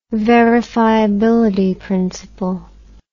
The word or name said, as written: verifiability principle